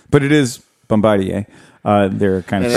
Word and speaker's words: Bombardier: It looks like a lot like a Bombardier. But the... – The deposit's going to be $16,000 a piece. – It's Bombardier. – Bombardier. So Sorry, sir. It's Bombardier.